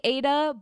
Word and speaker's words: Ai-Da: It's, I think you say it like Ada, but it's AI-DA, as in like artificial intelligence-DA.